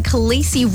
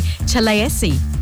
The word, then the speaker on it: Khaleesi: Well, Khaleesi is, of course, Queen in Dothraki, but David Peterson, the linguist who created the Dothraki language, says we shouldn't be pronouncing it Khaleesi, but actually Chaleesi.